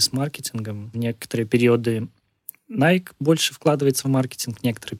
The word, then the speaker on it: Nike: Тема произношения — это на самом деле отдельная тема, потому что если прям совсем правильно, то да, это Nike, это Adidas, это Э, Сокони.